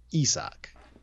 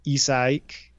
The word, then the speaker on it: Isaac: So in the pronunciation guide and hearing that Shepherd and what's going around the conventional wisdom, it is Isak, or maybe even three syllables, which would be Isaik.